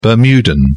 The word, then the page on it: bermudan: British English